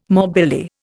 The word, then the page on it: morbilli: Indian